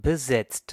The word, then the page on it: busy: /ˈbɪ.zi/